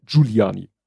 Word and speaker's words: Giuliani: Ja, jetzt habe ich das Problem zu sagen, äh, Giuliani spricht man Giuliani. Also er sagt Giu, Giuliani. Mhm. Äh, wahrscheinlich so wie Giovanni.